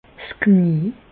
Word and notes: 's Knie: Bas-Rhin; Reichshoffen

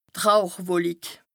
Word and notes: d' Rauchwolik: Bas Rhin

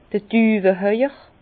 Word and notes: d'r Düwehauer: Bas-Rhin; Herrlisheim

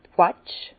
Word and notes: d' Quetsch: Bas Rhin; Herrlisheim

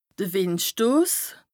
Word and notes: de Wìndstos: Bas Rhin; Strasbourg

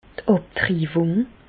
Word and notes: d' Àbtriwùng: Bas-Rhin; Reichshoffen

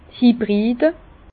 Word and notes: d' Hybride: Bas Rhin; Herrlisheim

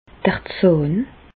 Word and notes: d'r Zàhn: Bas-Rhin; Reichshoffen

